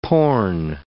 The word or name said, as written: porn